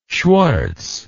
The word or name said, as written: schwarz